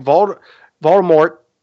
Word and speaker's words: Voldemort: I mean, they allude to it, but at the same time, like, Voldemort, because I got to pronounce it that way, because apparently, according to Rowling, we've been pronouncing it wrong. You have to pronounce the T at the end.